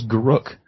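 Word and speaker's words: Garruk: and it's Garuk, not Garuk, as I've been pronouncing him. – So wait a minute. – It's not Garuk? – Are you telling me that the Canadian guy was pronouncing things the same way that I do, and we were both wrong? That's weird. Yeah. Don't I have to kill myself now? – Yeah, apparently his name is Garuk.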